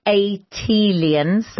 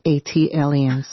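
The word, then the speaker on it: ATLiens: Tayari, just as Jill did for Philly, as an unofficial ambassador for Atlanta, an A-T-lians. – That is pronounced A-T-aliens. – Ah, thank you. – Like Atlanta alien, A-T-aliens.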